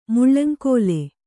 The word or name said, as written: ಮುಳ್ಳಂಕೋಲೆ